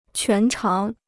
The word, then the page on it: 全长: quán cháng